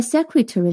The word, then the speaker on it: secretary: 要注意一下 secretary 的读音，secret 后面加上了 a r y 以后，第一个 e 不再发为 e，在 secret 里面发音是 secret，而在 secretary 里面，它发音变成了 a，就跟这个 bad 的 a 发音一样。重音在低音节 secretary，第二个一仍旧发成 a secret。